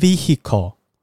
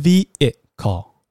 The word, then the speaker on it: vehicle: 比如说像刚刚这个交通工具 vehicle，它这个拼音之中虽然有个 h，可这个 h 其实是不发音的哦，它不是念 vehicle，不是念 vehicle 这样子哦，哦，那它是要念 vehicle，vehicle 就是 vehicle 这样子。